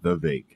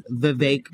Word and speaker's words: Vivek: I thought you said the Vake, and I was like... – No, it's because people think it's Vivek. – Vivek, and it's Vivek because it rhymes with cake.